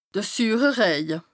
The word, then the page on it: de süüre Rëje: Bas Rhin